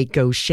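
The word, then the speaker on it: Gauthier: she put, "Say Gaucher, y'all." So to help us with that pronunciation.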